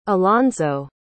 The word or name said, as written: allonzo